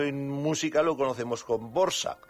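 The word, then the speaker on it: Dvořák: Borsat se escribe Dvorak, ¿vale? Con V y vale. Terminado con K. Pero en checo se dice Borsak.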